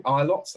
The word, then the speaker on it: Ilott: Ilott, that's how you pronounce it, yeah.